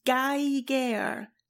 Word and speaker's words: Geiger: And he writes, "If you've never heard that word said aloud, it's pronounced Geiger, like tiger."